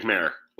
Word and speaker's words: Mayer: Yeah, Jake Mayer. – Like Mayer. Okay. It's not Meyer, it's Mayer.